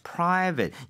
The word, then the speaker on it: private: 네, private. 이거 ATE라는 스펠링은 8이 아니라 에 가깝다라고 한 적이 있었어요.